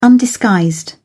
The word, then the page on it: undisguised: British English